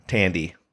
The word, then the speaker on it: Thandie: The age is silent. – Just wanted to Yeah, that's true. That is true. Tandy.